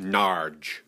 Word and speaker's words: Norwich: No, you're saying it wrong. It's pronounced Narge.